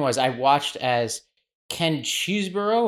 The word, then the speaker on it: Chesebro: Well, his lawyer says Cheesebro, which means that must be the real pronunciation.